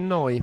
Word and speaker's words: Neu: E per esempio i battiti ossessivi di Allo Gallo dei Neu. – La pronuncia è noi. – La pronuncia è noi. – Il dittongo tedesco.